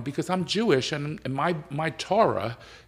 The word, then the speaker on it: Torah: In the Torah, is that how you pronounce it? – Torah.